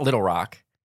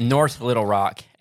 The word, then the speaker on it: North Little Rock: It's not Little Rock. – North Little Rock. – North Little Rock.